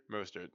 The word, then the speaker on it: Mostert: Mostert.